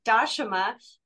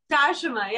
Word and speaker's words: Dashama: Just wanted to, my name is pronounced Dashama, but some people call me Dasha if it's hard to remember the pronunciation. – So yeah, one more time, Dashama. – Dashama, yeah, but you can call me Dasha.